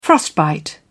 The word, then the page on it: frostbite: British English